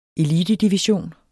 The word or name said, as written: elitedivision